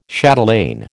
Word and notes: chatelaine: American English

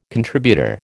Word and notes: contributor: American English

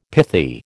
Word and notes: pithy: American English

